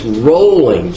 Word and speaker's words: Rowling: No, my kids would say, that's not how you pronounce the name. It's Rowling, J.K. Rowling.